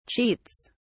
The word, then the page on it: cheats: British English